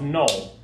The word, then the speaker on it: Noel: It's a French name. It's pronounced Noel. But my name is Joshua Noel Sullivan.